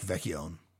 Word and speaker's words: Vecchione: Finish off with Mr. Electricity, 1967, Mike Vecchione. – Excellent pronunciation on that Italian, Vecchione, by the way.